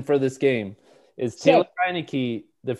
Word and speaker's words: Heinicke: Heinicke, I believe. Isn't it Heinicke? Yeah.